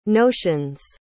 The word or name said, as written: notions